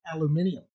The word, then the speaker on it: aluminium: By the way, in Britain and Australia, they pronounce it aluminium.